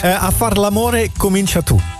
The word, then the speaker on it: A far l'amore comincia tu: A far l'amore comincia tu.